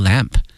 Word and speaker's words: lamb: Traditionally, 哈，传统哈，一般情况下 roast 这个词 R-O-A-S-T 表示烤 whole lamb 整个一个羊哈，烤全羊叫 whole lamb L-A-M-B 注意一下这个 B 是不发音的 to honor guest 后面是招待客人，这个招待用的是 honor 荣誉这个词 honor guest and celebrate festivals and special occasions 以及呢庆祝节日和呢一些比较特殊的一些场合。